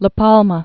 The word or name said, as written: La Palma